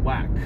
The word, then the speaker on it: WAAC: The first is an acronym, which is spelled W-A-A-C and pronounced WAC.